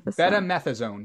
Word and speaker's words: betamethasone: Betamethasone, yeah. – Betamethasone.